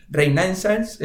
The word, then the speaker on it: Renaissance: Em, luego tienes hedge funds cuantitativos, pues el famoso Reina, Reina, joder, siempre lo pronuncio mal, Renaissance,